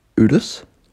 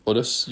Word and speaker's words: Urus: I'm not sure if I'm pronouncing it. U-R-U-S. – Urus? – Urus. Urus. – Oh, the way you said it made it sound like it was with a D.